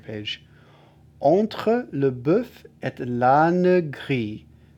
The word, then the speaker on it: l'âne: So this is, I mentioned that certain syllables, you'd normally say l'âne, but when it's sung to two notes, it's l'âne, l'âne gris. But you don't put too much emphasis on the ne. It's, you kind of pass through it.